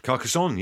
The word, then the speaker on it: Carcassonne: He said Carcassonne. Have I said that right? – Carcassonne, yes.